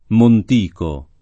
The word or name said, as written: Montico